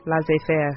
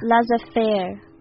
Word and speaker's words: Laissez-faire: Yeah, so how to pronounce the French? – Laissez-faire. – Laissez-faire.